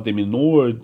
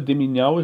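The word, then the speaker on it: de Minaur: Demiñawa, para quem não conhece, tem 19 anos, é 45 do mundo, joga pela Austrália, nasceu na Espanha, inclusive a pronúncia do sobrenome dele é meio polêmica aí, tem gente que fala Deminor, só que originalmente em espanhol é Demiñawa, inclusive com o tio em cima do M, em cima do N, né, que não...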